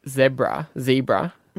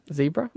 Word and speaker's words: zebra: When one of the animals gives the zebra. – Mm. – How do you say that? Zebra? – Zebra. Mostly. – Anyway, I think an American is zebra.